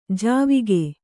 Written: ಜಾವಕ್

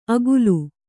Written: ಅಗುಲು

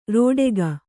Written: ರಟ್ಟಿಕ್ಕು

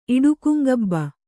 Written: ಇಡುಕುಂಗಬ್ಬ

